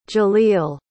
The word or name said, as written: jalil